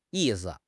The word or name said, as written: yi4 si5